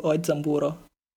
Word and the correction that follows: Kenzaburo Oe: Oezamburo. – Che, Kenzaburo Oe.